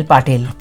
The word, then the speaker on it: Patil: In the 80s, I used to watch Smita Patel in Mandi. – If only you got the name right. Not Patel, Patil.